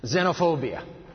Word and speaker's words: xenophobia: ponder this word. Xenophobia. The X is pronounced like a Z.